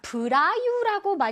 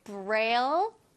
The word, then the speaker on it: Braille: 자, 브라유라고 많이 읽는데요. 한국어로는 읽을 때 브라유라고 읽는다고 합니다. 근데 브라유라고 하면 뭔가 보라유 이러는 것 같아서 자, 우리는 Braille라고 멋지게 발음을 해주세요. 오케이? Braille, Braille.